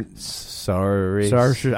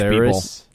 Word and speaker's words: Saoirse: Saoirse. – I don't know how to say her name because Irish Saoirse.